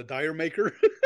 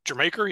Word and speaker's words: Jamaica: Do you call it a dire maker? – Jamaica, yeah. – Well, yes, as it's supposed to be pronounced, but until we knew that for years, I know I called it dire maker, and I heard DJs in America call it dire maker.